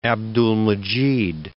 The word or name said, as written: Abdulmecid I